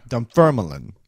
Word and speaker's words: Dunfermline: For the record, it's... Now, he breaks it down here with asterisks and underscores and such, but it looks like Dunfermline. – Dunfermline. – Yes, emphasizing the second syllable.